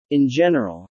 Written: in general

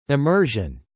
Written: immersion

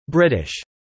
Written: Brittish

